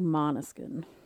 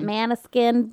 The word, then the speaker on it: Måneskin: Måneskin, M-A-N-E-S-K-I-N, and they have little umlauts over the A's. – Yeah. – So they pronounce it like Måneskin. – Right. So the umlaut means it's pronounced differently than what you think.